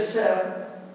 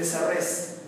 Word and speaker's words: Serres: El diálogo que establecí es entre Michel Serre, se escribe Serres, Michel Serre, y Gilles Deleuze.